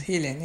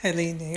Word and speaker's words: Helene: And much of it is from the point of view of a Yazidi captive, Helene. Am I saying that correctly? – Yes. – Okay, Helene. – Yes, Helene, yeah. – Who does... – Yeah, Helene, you're right.